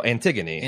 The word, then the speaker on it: Antigone: How do you pronounce her full name? It's Annie for short, but... – Oh, Antigone. – Antigone.